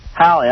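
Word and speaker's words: Halle Berry: At the movie premiere, Swordfish, when Hail Berry's breasts were showing. – How come you say Hail Berry? – Well, how do you say it? – Hallie, I'm sorry.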